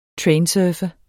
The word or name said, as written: trainsurfe